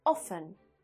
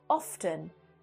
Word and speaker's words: often: Now, originally in old, old English, the only correct way was often, often. But now many, many people pronounce it often, often. And Cambridge and Oxford dictionaries both accept this pronunciation.